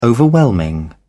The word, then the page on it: overwhelming: British English